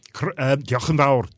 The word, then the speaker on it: Diolch yn fawr: Diolch yn fawr. Diolch. – Diolch yn fawr.